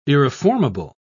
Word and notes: irreformable: British English

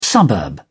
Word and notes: suburb: British English

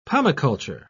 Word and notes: pomiculture: British English

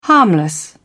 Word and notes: harmless: British English

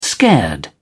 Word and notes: scared: British English

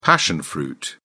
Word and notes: passion fruit: British English

